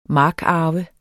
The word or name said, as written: markarve